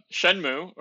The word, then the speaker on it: Shenmue: Yeah, Shenmue, I think is actually the correct way to say it.